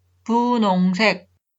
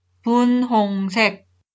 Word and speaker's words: 분홍색: La consonante hiut e es un sonido débil. Algunas personas pronuncian punongse, pero la pronunciación estándar es 분홍색.